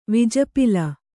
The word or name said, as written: ವಿಜಪಿಲ